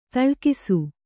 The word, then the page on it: ತಳ್ಕಿಸು: taḷkisu